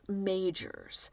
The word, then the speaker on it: Magers: I'm not positive how you pronounce that last name. It's spelled M-A-G-E-R-S. The great Robert Stack on Unsolved Mysteries says Majors, so I'm going with that.